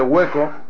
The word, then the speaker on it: Huecco: Pero luego escuchas el disco entero de Hueco, que se escribe H-U, o sea, es como Hueco, pero con dos Cs, y ves que no deja de ser el cantante de Sugarless.